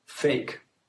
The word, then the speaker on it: PHEIC: The acronym, by the way, for public health emergency of international concern is PHEIC, pronounced fake. This